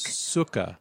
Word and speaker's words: Sukkah: And I also had to write down, don't say Sukkah Creek wrong. – Oh, you didn't have confidence right there, though. – No. – Sukkah Creek? – Sukkah. Like book.